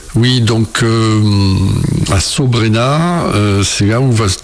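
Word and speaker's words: Saut-Brénaz: C'est-à-dire que justement, il y a une cascade, ça, c'est de là vient le nom de Solt-Brenna, comme on prononce Sobrena.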